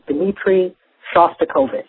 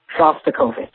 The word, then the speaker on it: Shostakovich: I just finished listening to today's download, and the pronunciation of the composer is Dmitri Shostakovich. Shostakovich. Also, I have a request.